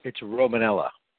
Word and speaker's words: Romanella: Yeah, and it's Romanella. – Did I say that wrong? Romanella.